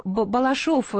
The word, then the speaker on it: Балашов: И Сергей из города Балашов, Саратовской области. Сергей, я правильно ударение поставила? – Да-да.